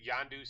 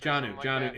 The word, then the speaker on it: Jonnu: Yondu Smith? – Jonnu. It's Jonnu. It's Hard J.